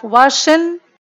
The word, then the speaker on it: Washington: We don't pronounce letter or sound G. And because it doesn't sound, many students, they're missing. We have Washington, and students missing that G.